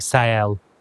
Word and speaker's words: Sael: Yes, it is the spelling of Sael is S-A-O-L, and it's pronounced S-A-E-L-L-E. That's the phonetic spelling, Sael.